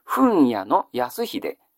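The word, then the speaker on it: 文屋康秀: これはちょっとルビー、ふとがないとわからない読み方ですね。ふんやの安秀、ふんやの安秀と読むんですね。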